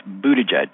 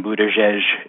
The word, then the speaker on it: Buttigieg: If you're going to keep talking about Pete Buttigieg through the primary season and possibly through the election or even his presidency, please pronounce it correctly. It's Buttigieg. Eli, please stop saying Buttigieg. It makes you sound like a college sophomore who's just returned from a European semester abroad.